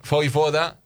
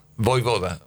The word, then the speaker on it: Vojvoda: Vojvoda. – Vojvoda. – Vojvoda. – Vojvoda. – Que no. – Sí, porque la J se pronuncia ahí.